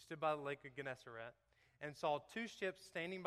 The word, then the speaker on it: Gennesaret: Gennesaret, that's exactly how you say that, because that's what Webster Dictionary says on the computer you can say pronounce, and that is draught.